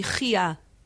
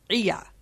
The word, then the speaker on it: Chiya: Apparently, Rabbi Chiya had difficulty pronouncing gutturals, and his chet sounded very much like an ayin.